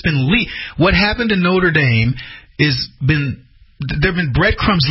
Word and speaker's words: Notre Dame: Notre Dame. That's the way we pronounce it here in the United States, but it's Notre Dame.